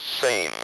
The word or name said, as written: same